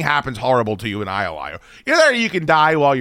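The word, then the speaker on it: jai alai: But everybody knows what IOI is. It's very strange that you do not. It's spelled, by the way, J-A-I.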